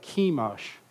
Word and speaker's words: Chemosh: There's other ways of pronouncing Chemosh. There's an interesting way that the Arabs pronounce it, but I don't remember. I was told that yesterday. Okay, the Moabites, Chemosh. So I'm going to say it the Anglo way, Chemosh.